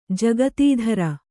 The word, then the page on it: ಜಕಾತಿದಾರ: jakātidāra